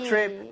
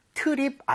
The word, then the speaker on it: trip: 자, T-R-I-P, trip 자체가 에이. 여행이죠. 발음할 때 트립 아니죠, 여러분들. 네? TR, 이 충돌을 하게 되면 치읓 비슷하게 됩니다. 그러니까 입 모양을 모으고 한다는 느낌으로 다시 한번 가볼게요.